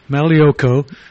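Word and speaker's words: Magliocco: Peter just got a lecture from Anne and said you have to pronounce this man's name correctly. It's Malioko.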